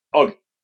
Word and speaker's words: Oggy: People think your nickname is Hoggy, but actually it's Oggy.